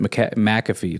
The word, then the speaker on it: McAfee: And it's like, no, you pronounce it McAfee. It's like, no, you don't. It's not Mac. It's Mick. First of all, Mick, not Mac. That's M-A-C.